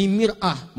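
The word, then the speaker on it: mir'ah (مرآة): Ini mar'ah, ini mir'ah. Maknanya bisa beda. Silakan lihat. Ini mar'atun, ini mir'atun. Yang ini perempuan. Yang ini cermin.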